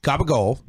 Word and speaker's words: capicola: Gabagol is capicola. That's how you would spell it.